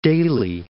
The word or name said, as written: Daly City